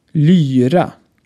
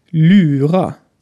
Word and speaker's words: lyra: Lyra hingegen ist ein antikes Saiteninstrument und hat ein Y. Lyra. Mit einer verdeutschten Aussprache würde es so klingen. Lyra. Für uns Schweden klingt das eher wie Verarscher als Musik.